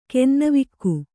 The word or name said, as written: ಕೆನ್ನವಿಕ್ಕು